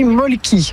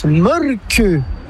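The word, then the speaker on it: Mölkky: Inventé en Finlande en 1996, ce jeu de quilles s'écrit M-O-T-R-E-M-A-L-K-K-Y. – Ça s'écrit Molki, mais ça se prononce Molke. – Le Molki. – Plutôt un E qu'un E, mais pas un U. Comme ça fait Molke.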